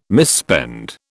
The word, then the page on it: misspend: British English